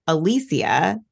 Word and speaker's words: Alicia: And so I was Alicia because that's how my name is traditionally pronounced.